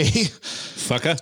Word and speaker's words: Fuqua: Oh, what is, how do you pronounce that last name? F-U-Q-U-A. – Fucka.